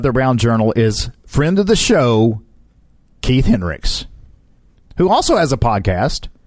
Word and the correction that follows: Heinrichs: Heinrichs, actually. It's pronounced Heinrichs,